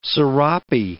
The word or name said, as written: serape